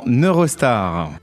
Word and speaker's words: Eurostar: Alors, en Eurostar.